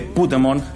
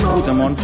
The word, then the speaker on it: Puigdemont: Para permitir que Puigdemont. – El poder que hay en Pokémon. – Puigdemont. – Hazte con todos. – Pues hazte con todos. Puigdemont. – Te diré que es difícil decirlo peor.